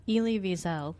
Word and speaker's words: Elie Wiesel: There's a quote by him that Elie Wiesel. Is that how did I say it correctly? Did I say it one more time? – Elie Wiesel.